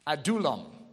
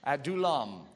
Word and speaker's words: Adullam: Adullam. Isn't that good? I gave you that little pronunciation guide there. Adullam.